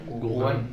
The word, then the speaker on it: Wuhan: Y de hecho en Wuhan, ¿o cómo se pronuncia? – Wuhan.